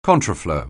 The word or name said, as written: contraflow